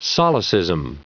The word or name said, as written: solecism